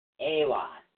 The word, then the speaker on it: Ehwaz: EWAS is spelled E-H-W-A-Z. It's pronounced A-Y-W-A-H-Z, AWAS.